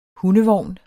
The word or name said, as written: hundevogn